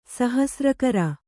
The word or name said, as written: ಸಹಸ್ರಕರ